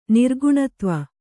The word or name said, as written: ನಿರ್ಗುಣತ್ವ